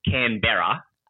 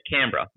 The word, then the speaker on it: Canberra: The town's called Canberra. C A N B E double R A. So I guess if you're ah, going to say it how it's written, it's Canberra. Ah. – Um, but we're Aussies and we're lazy, so we say Canberra, Melbourne, Brisbane, you know, you hear American accents say Melbourne.